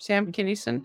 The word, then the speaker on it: Sam Kinison: Sam Kinison.